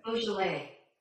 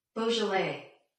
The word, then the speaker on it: Beaujolais: Is that how you say it? – Beaujolais. – I think you say it something like, hold on. – Beaujolais.